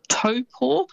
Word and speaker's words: Taupo: But famous spots including Taupo, so quite often you'll hear that said as Taupo. – Yes. – It's almost how it's spelt, but that Taupo is actually pronounced Taupo.